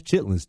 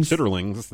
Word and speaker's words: chitterlings: Why is chitlins dangerous to say on the radio? – If you pronounce it like it is on the plastic bin that they come in, chitterlings, then yeah. But that's not.